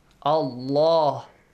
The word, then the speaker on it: Allah: The rule says, if there is a fatha or a dhamma right before the word Allah, it is pronounced heavily. Allah. If there is a kasra before, it is pronounced lightly.